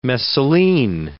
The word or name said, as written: messaline